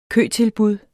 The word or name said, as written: køtilbud